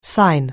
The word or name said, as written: sign